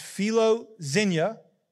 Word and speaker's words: philoxenia: In fact, the Greek word for hospitality is what we call, it's pronounced philoxenia, and it actually means the love of strangers or to show love to strangers.